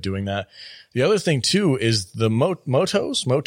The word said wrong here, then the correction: MUTOs: Mutos, yeah.